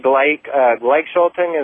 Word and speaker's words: Gleichschaltung: And the idea of the Gleichschaltung, is that what it, how it is pronounced? – Yeah.